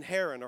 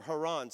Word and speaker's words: Haran: Terah became the father, watch this, of three sons, Abram, who becomes Abraham, Nahor, and Haran, or Haran, some people pronounce it that way.